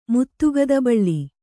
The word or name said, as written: ಮುತ್ತುಗದಬಳ್ಳಿ